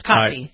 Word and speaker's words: Kotb: Nobody knows how to say her last name, but it's Kotb.